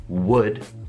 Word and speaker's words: would: Effettivamente è molto semplice, perché per formare la condizionale in inglese bisogna solo prendere la parola would che si scrive con l'h ma non si sente l'h, si sente così would,